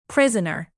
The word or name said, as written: prisoner